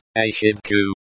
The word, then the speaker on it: ошибку: Pronounced ошибку, as in R, шиб, as in ship, ку, as in cook, is in the accusative case because it is the direct object of the verb.